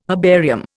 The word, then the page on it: herbarium: Indian